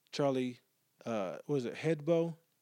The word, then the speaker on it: Charlie Hebdo: I think I've probably responded and erased to about 5 to 6 different posts on this whole Charlie, Hebdo? I don't want to mispronounce it. I don't know how to pronounce the it is Charlie Hebdo.